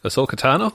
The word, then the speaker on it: Ahsoka Tano: Ahsoka Tano? – That's the one, yeah.